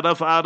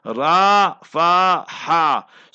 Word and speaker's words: Rafah: We must learn the proper Islamic Arabic pronunciation. Not Gaza, Gaza. And not Rafah, Rafah. Ra fa ha. So remember, re fe he. So that is how you pronounce it, right on the southern side of Gaza, next to Egypt, Egyptian border.